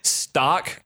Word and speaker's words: Stok: The Aldi Stock cold brew. It's spelled S-T-O-K. – Oh. – I think it's pronounced stock.